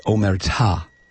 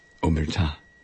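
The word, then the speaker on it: omertà: It's omerta. – Oh. – Yeah, sorry. – That's all right. The accent is on the last syllable.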